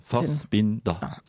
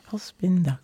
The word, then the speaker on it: Fassbinder: Sind aber drei Silben, Fassbinder. – Fassbinder, genau, richtig.